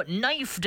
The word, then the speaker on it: knife: And you look at the way knife is spelled, and you're kind of thinking, why do they got that K on there? That's kind of a waste of a letter. Well, actually, way back in the day, the K in the word knife did used to be pronounced. But, you know, somewhere along the way, they just kind of cut it out of there.